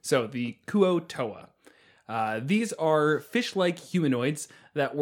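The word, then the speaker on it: Kuo-toa: This week's monsters are the Koa Toa. Oh, I'm sorry, the Kuo Toa? Kevin, help me out here. – Yeah, Kuo Toa.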